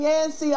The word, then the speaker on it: Yancey: Nancy rhymes with Yancey. But it's Sharon Yancey, a woman's glory.